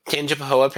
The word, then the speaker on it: Tangipahoa: Tangipahoa. Did I say that right? – Tangipahoa Parish in Louisiana. – Well, hey, gold star for me.